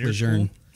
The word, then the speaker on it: Lejeune: Comment it's pronounced Lejeune.